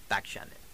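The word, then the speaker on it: Bakshandeh: That's spelled SMARTMAN, the numbers 400, the at sign, YAHOO, the dot, com, COM, and my Facebook name is Ariel Bakshanan.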